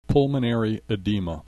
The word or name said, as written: pulmonary edema